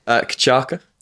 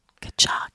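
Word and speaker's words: cachaça: Kachaca. – Kachaca.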